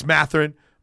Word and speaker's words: Mathurin: And then Benedict Mathurin. Matt, did I say? Okay. It's Mathurin.